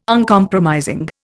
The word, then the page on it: Uncompromising: Indian